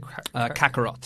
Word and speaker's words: Kakarot: Kakarot.